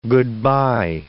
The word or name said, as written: good-by